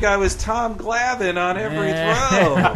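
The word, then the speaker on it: Glavine: I believe it's pronounced Glavin.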